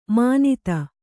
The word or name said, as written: ಮಾನಿತ